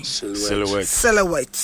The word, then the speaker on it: silhouette: Silhouette. – Yes. Silhouette in the spelling is different from the way it's pronounced.